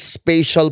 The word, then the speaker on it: spatial: And it's all these spatial puzzles. I'm not mispronouncing special. Spatial. S-P-A-T-I-A-L. – Spatial po. – Spatial and spatial. Because it is spatial.